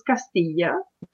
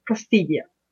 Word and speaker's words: Castilla: So actually, that part has always been the same, which is Castilla. – Yes. – But that's it's true, that's a Spanish pronunciation. So Castilla, right? – Right.